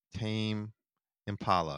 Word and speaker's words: Tame Impala: And I want to start with Brendan's pick, uh, his top pick, uh, which is Tame Impala.